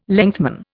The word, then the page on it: lengthman: Indian